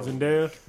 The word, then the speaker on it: Zendaya: I think it's pronounced Zendaya. – No. Zendaya.